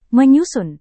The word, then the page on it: menyusun: Indonesian